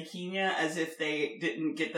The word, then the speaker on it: Kenya: But yeah, it's Kenya. – I didn't even know that was racist. – It is the colonial pronunciation of the nation. So it's like still saying Peking instead of Beijing.